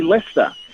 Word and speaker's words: Leicester: So apparently some centuries ago now, my family was known for its cheese in County Leicester, which is, of course, a cheese. My middle name is Lester, spelled the same way as that county.